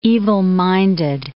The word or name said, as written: evil-minded